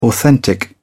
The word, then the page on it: authentic: British English